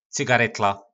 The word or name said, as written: Cygaretla